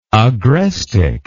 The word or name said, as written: agrestic